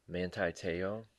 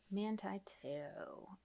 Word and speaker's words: Manti Te'o: Manti Te'o. – Manti Te'o.